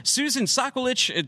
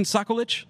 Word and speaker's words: Soccolich: Susan Sokolich, did we say that correctly? Susan Sokolich? – Yeah.